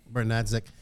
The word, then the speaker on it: Bernadzik: You have, like I said, you have Bernard Zach. Am I saying his name right? Bernard Zach? – Banasik, yeah. – Bernadzik, yeah, who I got introduced to last year.